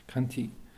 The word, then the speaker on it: Khanti: So the quality of what's called patient endurance is the paramita of Kanti, which is spelled Khanti, Kanti, and that is it's a paramita.